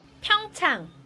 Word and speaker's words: Pyeongchang: How do you pronounce it? – Pyeongchang, I think. – Yeah, I was originally going for Pyeongchang, but that's wrong. Google pronounces it like this. – Pyeongchang. – Very helpful to Google. – Right. – There we go. So that's the correct term and way of saying it.